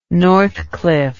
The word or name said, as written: northcliffe